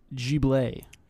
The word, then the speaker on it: Ghibli: It's pronounced Ghibli.